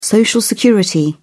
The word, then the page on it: social security: British English